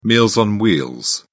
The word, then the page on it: meals on wheels: British English